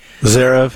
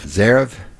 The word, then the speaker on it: Zverev: The German guy, Zverev. – Zverev.